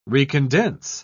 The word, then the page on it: recondense: British English